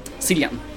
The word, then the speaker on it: Cillian: Nej, det jag vet att eh, Cillian Murphy, eller Killian Murphy, hur uttalas det nu då? Cillian. Är det så? Mm.